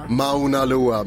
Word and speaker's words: Mauna Loa: Mauna Loa. Mycket riktigt bra uttal.